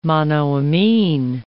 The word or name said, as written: monoamine